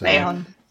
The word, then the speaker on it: Mahon: Alex Mahon? I don't know quite how you pronounce this. – Mahon. – Okay. Yeah, Mahon.